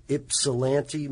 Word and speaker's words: Ypsilanti: In Ypsilanti, Michigan.